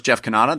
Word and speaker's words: Jeff Cannata: I'm your host, Jeff Cannata.